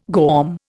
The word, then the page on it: GOM: Indian